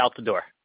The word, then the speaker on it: Altidore: You know, I can't pronounce the guy's name. Altidore? – Josie Altidore. – Josie Altidore. I always mess that up.